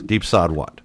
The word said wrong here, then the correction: Deep Said What: Deep said what. – Right, because that's how it's spelled, S-A-I-D.